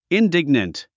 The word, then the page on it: indignant: indíɡnənt; インディグナント